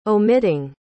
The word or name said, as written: omitting